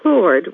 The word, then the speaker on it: Plord: Lynn rhymes with thin, which I am not, and Plord rhymes with Ford, as in Henry Ford.